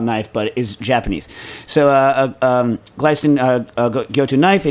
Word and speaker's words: Glestain: It's spelled Glestain, but I believe it's pronounced Glystain. Glystain.